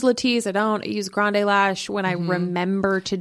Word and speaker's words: Grande Lash: You said that right. – I used to have, yeah. – Yeah, because some people think it's Grand Lash.